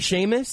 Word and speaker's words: Seamus: Is it Seamus? – Yeah. – There's an implied H? – Yeah.